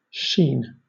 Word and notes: sheen: British English

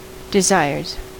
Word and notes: desires: American English

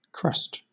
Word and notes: crust: British English

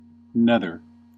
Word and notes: nether: American English

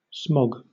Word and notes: smog: British English

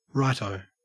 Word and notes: righto: Australian English

